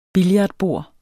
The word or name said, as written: billardbord